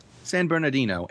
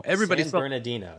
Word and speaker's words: San Bernardino: I'm not even going to pronounce that city correctly. – San Bernardino. – San Bernardino, thank you.